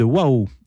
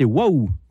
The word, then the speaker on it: Wau: Leur prochaine mission les amène au Sud-Soudan, dans le diocèse de Waouh. Eh et oui, prononcer Waouh.